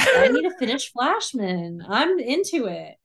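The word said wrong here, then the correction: Fleishman: Fleischman. You're saying Flashman. – How do you say it? – Fleisch, like fly swatter. Fleischman.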